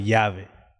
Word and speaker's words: llave: So like, let's say, like two L's, like LLAVE, that would be key in Spanish. You pronounce it Yave. – Yave. – Yeah, like two L's back to back, it makes a Y sound.